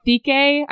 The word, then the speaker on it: Dike: So I had this whole thing around Kelly being like a priestess of Thike, I think it's pronounced, the Greek goddess of justice and purity or something like that. But I was so nervous about the way to pronounce the god's name because it's spelled D-I-K-E.